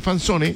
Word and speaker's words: Fanzone: Se escribe F-A-N, Fan. Z-O-N-E, Fanzone, se escribe Fanzone, se pronuncia Fanzone.